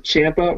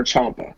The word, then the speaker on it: Ciampa: And for people that might hear it, you can pronounce it Ciampa or Ciampa, depending on how you want to.